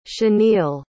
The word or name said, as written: chaneil